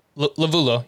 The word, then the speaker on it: Lovullo: Lovullo.